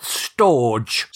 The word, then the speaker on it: storge: Then you get the ones that are difficult to pronounce, and it's spelt Storge. – Storge. – To me, that sounds like a steam pudding, but that's probably a whole new episode. It's storge. It's spelt S-T-O-R-G-E, storge.